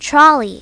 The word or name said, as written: trolley